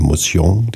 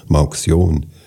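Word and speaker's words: Mauxion: Die Firma nannte sich französisch ausgesprochen Motion, die Saalfelder haben immer gesagt Motion.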